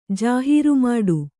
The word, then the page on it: ಜಾಹೀರುಮಾಡು: jāhīru māḍu; kannada